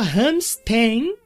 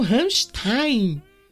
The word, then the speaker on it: Rammstein: Ele escreveu assim, ó, muita gente fala Hamstein, eu falei agora que vocês perceberam, mas eles têm, eles mesmos têm músicas onde eles falam Rammstein.